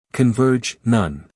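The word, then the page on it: converge: uk kənˈvɜːdʒ; en-us kənˈvɜːrdʒ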